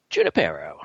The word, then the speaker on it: Junipero: Junipero.